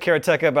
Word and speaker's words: Karateka: And so, first of all, Jordan Mechner does pronounce it Karateka, but I still don't like that. – I thought it was Karateka. – It should be. Because it's a real word, Japanese word that just means someone who practices karate. I don't know why adding ka at the end would change the pronunciation of the word.